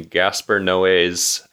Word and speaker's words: Gaspar Noé: We are going to be watching Gaspar Noé's, I think that's how you pronounce his name.